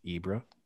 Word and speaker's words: Ibra: You can just, if you really wanted to, you can just be one of those people who just says Ibra. – Ibra.